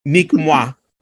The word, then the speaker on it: Nique moi: how do you say that in French? – Nique moi. Nique moi means fuck me.